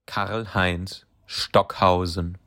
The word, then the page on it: Karlheinz Stockhausen: German